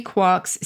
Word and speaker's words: quark: The allusion to three quarks seemed perfect. I needed an excuse for retaining the pronunciation quark despite the occurrence of Mark, Bark, Mark, and so forth in Finnegans Wake.